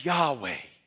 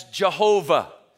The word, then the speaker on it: Yahweh: Most English translations would pronounce this name as Yahweh. Some pronounce it as Jehovah. But Jehovah is literally a transliterated spelling, taking the Hebrew word for Adonai, Lord, adding vowel points to it and getting Jehovah.